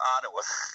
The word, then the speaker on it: Ottawa: We pronounce Ottawa. No, it's Ottawa.